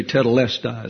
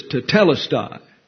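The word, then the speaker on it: tetelestai (τετέλεσται): We have a tendency to want to accent certain syllables in English. So we say tetelestai. It's always that next to the last syllable. But Greek throws accents all over the place. And sometimes they're hard to pronounce because of that, especially when a diphthong is involved. But it's tetelestai.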